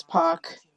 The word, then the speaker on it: Pac: It felt good seeing Pac. I don't even know if I'm pronouncing his name right. – It's Pac.